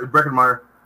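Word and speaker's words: Breckin Meyer: Yeah, that's right, Breckenmeyer.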